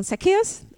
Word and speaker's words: Zacchaeus: Zacchaeus, I'm always saying it wrong. Zacchaeus? Okay, there you go.